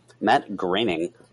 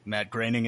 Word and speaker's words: Groening: Now, do you guys know how to say his name? I do. – Matt Groening. – Matt Groening is correct.